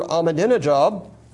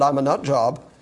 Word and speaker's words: Ahmadinejad: As soon as you hear Ahmadinejad, I pronounced it correctly, although I've been known to pronounce it I'm a nut job.